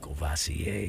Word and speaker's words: Courvoisier: Oh, you used to drink Cavassier? – Yeah, you know Matt makes fun of me if I mispronounce. But I know he has a little dyslexia too. If I mispronounce a word. Mm-hmm. Were you always able to pronounce Courvoisier when you saw it? – Courvoisier. – When you first saw Courvoisier.